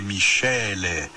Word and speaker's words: miscele: Que em italiano, le miscele. Michele são Michele, mas se escreve, pelo amor de Deus, não é aquele Michele, não. É Michele, que se escreve com M-I-S-C-E-L-E, que quer dizer misturas, né?